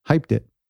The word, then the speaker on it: Hypeddit: That's spelled H-Y-P-E-D-D-I-T, Hypedit.